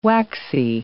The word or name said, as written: waxy